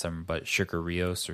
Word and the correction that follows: Sigur Rós: Sigur Rós. – Yes. – Sigur Rós.